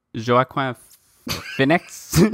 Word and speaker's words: Joaquin Phoenix: Joaquin Phoenix.